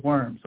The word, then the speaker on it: Worms: It's pronounced Worms.